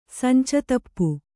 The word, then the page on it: ಸಂಚತಪ್ಪು: sanca tappu